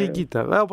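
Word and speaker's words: Higuita: Το λέω γιατί τα νεότερα παιδιά, εντάξει, μπορεί να μην τον ξέρουν, αλλά η ισπανική προφορά είναι η Γκίτα.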